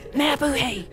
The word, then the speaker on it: mabuhay: Hello, It's mabuhay. That's how it's pronounced.